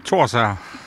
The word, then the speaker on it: Thorsager: Thorsager.